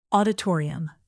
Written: auditorium

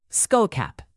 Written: skullcap

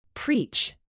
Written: preach

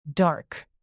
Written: dark

